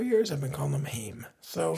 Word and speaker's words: Haim: I think it's Haim. Like Haim. – Mm-hmm. Sounds German. – Yeah, it does. A little bit in the pronunciation. But you're right. It is in doing, again, a little research for this, light research, listeners. It is Haim.